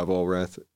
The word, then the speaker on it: Vollrath: Or you can look me up on Facebook, Levi Vollrath, and you'll be able to contact me there.